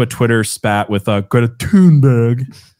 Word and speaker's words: Thunberg: Yeah, say it right. It's Thunberg.